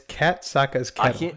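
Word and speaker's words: Kat Saka's Kettle: Bro, it's Kataska's Kettle. It's not that hard.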